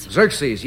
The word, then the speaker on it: Xerxes: Who is Xerxes? – Xerxes, yes.